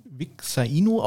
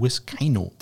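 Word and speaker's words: Vizcaino: Der wird nicht äh, Vixaino ausgesprochen, wie ich es immer sagen will. Äh, äh, er heißt Tristan Wiscaino.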